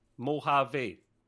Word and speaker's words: Mojave: You don't pronounce the J. Mojave.